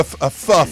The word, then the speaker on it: pho: That'd be P-H-O. You seem fe? – Pho is P-H-O is pronounced pho.